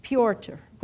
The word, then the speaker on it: Piotr: And then I looked at, I don't know how you would pronounce it, P-Y-R-O or P-Y-O-T-R? – Piotr, which would be Peter, right?